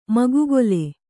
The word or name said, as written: ಮುಗ್ಗಲು